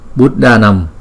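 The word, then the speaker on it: buddhānāṃ: Chữ D chữ H thì đọc mạnh hơn là chữ D không. Thí dụ như chữ D không, chúng ta đọc là Buddhanam.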